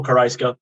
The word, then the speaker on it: Caraisco: It's Corporal CARAISCO. You got it.